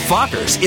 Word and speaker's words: Fockers: How do you pronounce it? – Oh, just like it's spelled. F-O-C-K-E-R. – Meet the Fockers in theaters December 22nd.